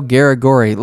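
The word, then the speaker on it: Garagorri: And then Darren Elkins revived his career against Edwardo Garigori, right? Is that how you say his name? Yeah, Edwardo Garigori.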